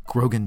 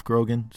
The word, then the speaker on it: Grogan: It's at Grogan Jeff, G-R-O-G-E, no, G-R-O, I can never spell my own name correctly. It's G-R-O-G-A-N-G-E-O-F-F, M-I-C-K-E-Y-M-O-U-S-E, okay.